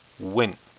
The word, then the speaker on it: Nguyen: Next, we have Supernatural Rising Sun, number 3, written by Peter Johnston and Rebecca Desartin, art by Diego Olmos, and cover by Dustin Nguyen. Nguyen. I owe that pronunciation to Red Lantern.